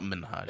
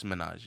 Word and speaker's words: Minhaj: What's Minhaj? – Is that how you pronounce it? If you're pronouncing it correctly? – No, it's not Minhaj. – It's Minhaj? – It's Minhaj, yeah.